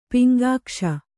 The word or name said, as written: ಪಿಂಗಾಕ್ಷ